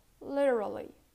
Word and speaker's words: literally: The next mispronounced word is literally. Literally. You pronounce the T like a D.